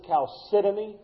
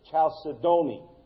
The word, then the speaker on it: chalcedony: The third is, some pronounce this chalcedony, some pronounce it chalcedony or chalcedony.